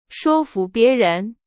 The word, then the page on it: 说服: Chinese